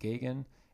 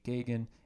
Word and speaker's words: Gagain: And if you are listening at home, you can always follow me on Instagram at I am Jake Gagan, and on TikTok at I am Jake Gagan, and Gagan is spelled G-A-G-A-I-N.